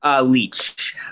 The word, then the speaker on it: Leach: Leach. It's pronounced Leach, L-E-A-C-H.